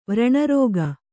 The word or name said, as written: ವ್ರಣರೋಗ